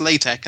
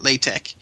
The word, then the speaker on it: LaTeX: We should probably explain, actually, that LaTeX, it is pronounced LaTeX, and it's very important you call it LaTeX. It's spelled capital L, small a, capital T, small a, capital X.